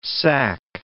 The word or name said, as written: sacque